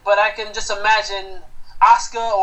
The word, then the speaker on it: Asuka: It's pronounced Asuka. Asuka. – Or is it Asuka? – Yeah, because okay.